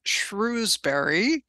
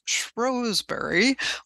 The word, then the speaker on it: Shrewsbury: She lived in a town that I always read as Shrewsbury, you know, with my American accent, and they actually pronounce as Shrewsbury or something close to that.